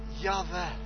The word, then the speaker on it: Yahweh: And Yahweh, there's no vocal in it. – A v tom není žádná znělá souhlas, samohlas. – When Jewish people pronounce God like Yahweh. – A židé vyslovovali Boha jako Yahweh. – It sounds like breathing.